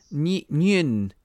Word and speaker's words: Nguyen: Yeah, because she's Su Lin Wing, isn't she? Isn't he? – Oh, it's one of those. It's Su Lin Nguyen, Nguyen is how it's spelt.